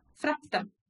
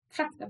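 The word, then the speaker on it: Frakta: Fracta. Fracta bag.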